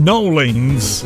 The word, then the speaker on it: New Orleans: the King of New Orleans. Although if you go there, they'll tell you it's pronounced Nolens.